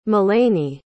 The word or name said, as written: melaney